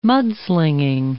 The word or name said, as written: mudslinging